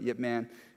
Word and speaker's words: Ip Man: It's spelled IP Man, but Yip Man is how you pronounce it.